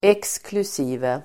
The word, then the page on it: exkl: eksklus'i:ve